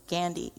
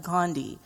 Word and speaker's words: Gandhi: So Mahatma Gandhi, I say his name different because I am Indian. That's how you say his name, Gandhi, not Gandhi. That's real Western.